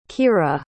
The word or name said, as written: keira